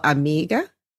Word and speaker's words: Amigues: Today is an absolute treat because I have the amazing Dr. Isabelle Amigues.